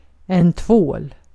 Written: sabun

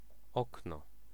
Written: fenêtre

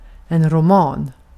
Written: roman

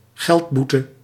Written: fine

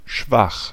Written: schwach

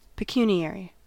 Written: pecuniary

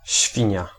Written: hog

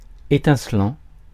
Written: étincelant

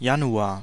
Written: Januar

